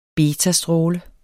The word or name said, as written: betastråle